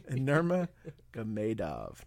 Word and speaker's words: Nurmagomedov: Nurmagomedov. Gamedov. So you gotta say it like in two different like two different words. Nurmagomedov.